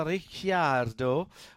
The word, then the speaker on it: Ricciardo: McLaren Mercedes, Lando in second, and Daniel Ricciardo, which that is the proper way to say it, even though he doesn't say it like that, because he can't be bothered. – Yes, too many syllables.